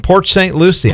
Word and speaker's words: Port St. Lucie: From Port St. Lucie.